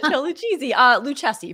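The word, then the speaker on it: Lucchesi: Lucchese, for those who prefer the correct pronunciation of names.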